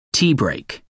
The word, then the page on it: tea break: American English